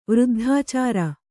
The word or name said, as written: ವೃದ್ಧಾಚಾರ